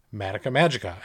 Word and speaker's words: Madoka Magica: So let's get started with Madoka Magica.